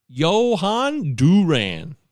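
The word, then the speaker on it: Jhoan Duran: and that's Juan Duran. I looked this up. It's Johan Duran. Johan Duran, I believe, is how I looked down the pronunciation.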